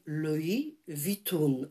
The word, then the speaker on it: Louis Vuitton: you all say it wrong, czyli wszyscy mówicie tak. źle. To się mówi Louis Vuitton,